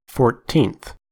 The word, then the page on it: fourteenth: American English